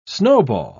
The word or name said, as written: snowball